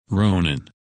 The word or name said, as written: Ronan